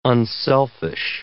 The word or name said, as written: unselfish